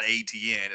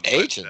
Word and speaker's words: Etienne: Uh, uh, Travis Etienne is is uh, the pronunciation on that, by the way, is not ATN. It's actually uh, Agent.